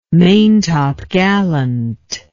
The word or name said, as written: maintopgallant